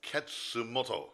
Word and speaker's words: Katsumoto: Kasumoto. That's not very nice. He's not a hunchback. – I'm saying it's K is silent. – His name is Katsumoto.